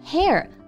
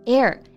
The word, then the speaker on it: heir: 对，我们刚刚说到了 inherit 是继承，那继承者、继承人啊，男性呢我们就用 heir 来表达，女性呢就是。Heiress 这两个单词我们都要注意一下发音啊，单词拼写呢都是 H 开头的，但是这里呢 H 不发音，不是 hair 而是 heir and heiress。